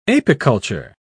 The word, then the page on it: apiculture: British English